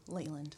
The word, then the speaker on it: Leland: I can't remember how I meant to pronounce it. – Leland. – Thank you.